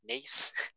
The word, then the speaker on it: Nice: I've always called them Nice. And my mom's like, no, it's nice. I'm like, no, but it's pronounced Nice. – It's not pronounced niece, it's a nice biscuit.